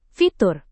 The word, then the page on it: fitur: indonesian